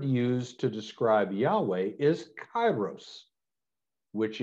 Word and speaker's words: Yahweh: Concept is a translation of what's known as the Tetragrammaton, the four letters in the Hebrew scriptures defining the nature of God, YHWH, pronounced by us as Yahweh, but by faithful Jews never pronounced.